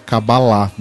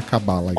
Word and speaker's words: Kabbalah: Eu fiquei sabendo que a pronúncia correta é Kabbalah, não é Kabbalah.